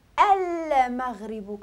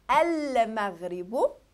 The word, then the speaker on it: المغرب: هنا ما عرف عندي هذه هنايا المغرب، ملي كنقول المغرب لا لا، الميم راه حرف قمري ماشي حرف شمسي. المغرب كنقولو كنطقو الا وكنطقو اللام.